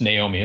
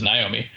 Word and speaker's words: Naomi: That's Naomi is how I say it. My wife says Naomi.